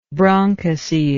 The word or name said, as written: bronchocele